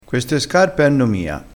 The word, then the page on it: mia: dialettale